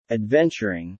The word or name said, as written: adventuring